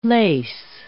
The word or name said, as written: lace